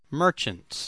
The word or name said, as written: merchants